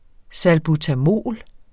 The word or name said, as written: salbutamol